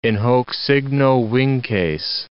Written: in hoc signo vinces

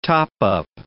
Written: top-up

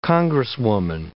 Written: congresswoman

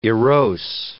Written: erose

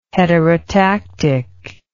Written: heterotactic

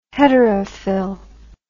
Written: heterofil

